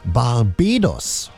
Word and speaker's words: Barbados: Die Flippers haben das in Deutsch, Barbados ist richtig, aber in Englisch nicht. Falsch ausgesprochen, ne? Barbados heißt diese Inselformation.